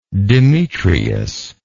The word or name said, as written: demetrias